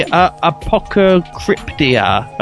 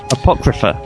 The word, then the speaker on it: Apocrypha: Ah, yes, the Apocryptia. How do you pronounce this? – Apocrypha.